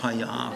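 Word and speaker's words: Haya (היה): Zweite Silbe betont, gell? Haya. Schwaben sagen auch oft Haya, aber die meinen was ganz anderes.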